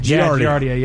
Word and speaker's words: Giardia: And is it Giardia? Is that how you say it? – Giardia? – Giardia, yeah.